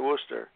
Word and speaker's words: Worcester: In England, they have W-O-O-S-T-E-R, and they pronounce it Worcester. And we have W-O-R-C-E-S-T-E-R, and pronounce it the same way, Worcester, as if there's no R.